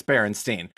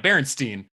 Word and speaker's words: Berenstain: No, I was going to say, no, you pronounce it, you know, the pronunciation is right, Berenstain. It's just that spelling, people don't know that there's an A in there.